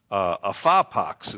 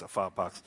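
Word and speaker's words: ephapax: It's a phapax is the way it's pronounced. Want me to spell that for you? It's not like chicken pox, a phapax. It's E-P-H-A-P-A-X.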